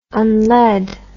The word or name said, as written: unlead